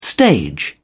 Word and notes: stage: British English

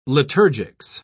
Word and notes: liturgics: British English

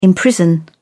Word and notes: imprison: British English

